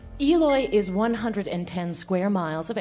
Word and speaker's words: Eloy: Eloy, Arizona. – Oh. How do you spell it? – E-L-O-Y. – Oh, that's how you would say it.